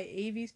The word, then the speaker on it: Avie: according to Avie, I think that's how you say it, A-V-I-E, Avie.